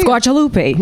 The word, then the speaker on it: Squarcialupi: Squarcialupi.